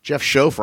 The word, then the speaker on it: Schoaf: Uh, Brian Reynolds, uh, Ashley Rice, Sophia, of course, Matt Punton, uh, Andy Averill, Jeff Schoaf rhymes with loaf.